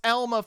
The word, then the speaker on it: Alma: Yes, it's still pronounced Alma, folks.